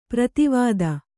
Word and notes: ಪರದೇವತೆ: para dēvate

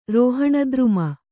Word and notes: ರೋಹಣದ್ರುಮ: Kannada

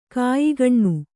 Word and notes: ಕಾಯಿಗಣ್ಣು: kāyigaṇṇu

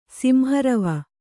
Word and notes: ಸಿಂಹರವ: Kannada

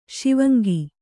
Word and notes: ಶಿವಾಂಗಿ: śivāngi

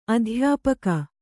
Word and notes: ಅಧ್ಯಾಪಿಕೆ: kannada

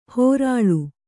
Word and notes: ಹೊರಳು: kannada

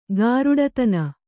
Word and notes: ಗಾರುಡತನ: gāruḍatana

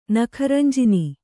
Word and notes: ನಖರಂಜಿನಿ: Kannada

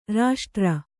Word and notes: ರಾಷ್ಟ್ರ: raṣṭra

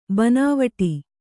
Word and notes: ಬನಾವಟಿ: banāvaṭi

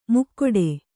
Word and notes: ಮುಕ್ಕೊಡೆ: mukkoḍe

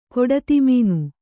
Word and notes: ಕಡುದುಮ್ಮಾನ: kaḍudummāna

